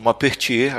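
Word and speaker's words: Maupertuis: É como, como o Pena tinha dito, o Mapertier, agora falei certo, ele, ele era um grande defensor da, dessa religiosidade dentro da ciência e tal.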